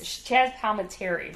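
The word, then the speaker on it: Chazz Palminteri: It had Chaz Palomero. I can't say his name. – I don't know who it is. – Sharon Stone, though. Kathy Bates. – Kathy Bates. – Chaz Palminteri, rather.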